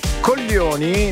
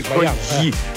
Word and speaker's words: coglioni: Coglioni non si dice coglioni come molti di coglioni. Si deve dire quasi con la L, coglioni. – Sì. – Questa è la pronuncia esatta, perché molti dicono coglioni.